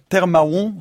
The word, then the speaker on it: Tè Mawon: Parce qu'on doit, il y a un accent sur le et. En fait, c'est Termaron. – Ouais. C'est un et comme le lait. – Ouais. – Et en fait, l'accent marque la chute du R du mot terre. – D'accord. – Donc voilà, on peut prononcer Termaron très très vite, Termaron. – Termaron. – Et comme ça, on n'entend pas les R.